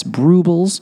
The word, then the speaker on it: Brewbles: I'm over here on their Instagram page, that's spelled B-R-E-W-B-L-E-S, Brubles, like brew and bubbles.